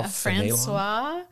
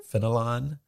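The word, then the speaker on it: Fénelon: Is that Francois Fenelon? – Fenelon.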